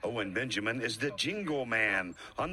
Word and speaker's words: Owen Benjamin: Owen Benjamin.